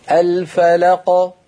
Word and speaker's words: الفلق: ولا تختميها بهمزة. اصبري اصبري. – اسمعي يا أختي، اصبري يا أختي، لا تختميها بهمزة. الفلق. تفضلي.